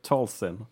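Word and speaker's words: Talzin: Mother Tolson. Ja, Det var egentlig en perfekt uttalelse.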